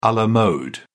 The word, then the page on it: a la mode: British English